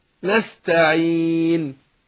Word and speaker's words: نستعين: لا، اقول نستعين، نستعين، لكن ما قولش نستعين، إن لا، خطأ، يبقى نستعين بس.